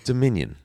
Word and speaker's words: Dominion: Democker. – Close. Not Democker. Dominion.